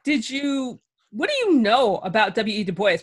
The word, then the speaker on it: Du Bois: And it's the Anglo pronunciation of Du Bois.